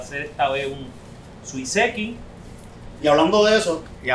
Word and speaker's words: suiseki: Suiseki. Suiseki.